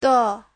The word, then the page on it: 的: de5